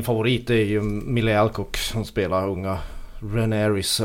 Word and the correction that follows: Rhaenyra: eller vad man ska... Hur, Rhaenyra. – Rhaenyra.